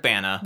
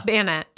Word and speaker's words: Bana: Eric Bana. – Bana.